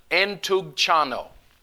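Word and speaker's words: entugchano (ἐντυγχάνω): The word in the Greek is a long Greek word, and it's pronounced entugchano.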